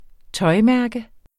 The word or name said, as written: tøjmærke